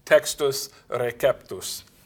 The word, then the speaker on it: Textus Receptus: And, as I was taught when I studied Latin to pronounce it, would be Textus Receptus. The C is always hard in Latin.